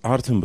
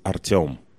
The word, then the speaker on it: Artem: Well, it's easy to pronounce Artem, but originally it sounds Artem.